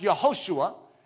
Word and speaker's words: Yehoshua: But Joshua is actually pronounced Yehoshua, which means Yahweh saves.